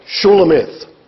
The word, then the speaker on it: Shulamith: We shall therefore call the bride Shulamith, which is how it is pronounced in the original.